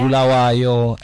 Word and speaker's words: Bulawayo: Bulawayo, and... – Yes. Oh, you said it right. Well done.